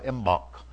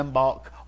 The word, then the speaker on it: Imbolc: February 2nd was a halfway point between the winter solstice and the spring equinox, and was a pagan holiday of Imbolc. It's pronounced two different ways, Imbolc or Imbolc.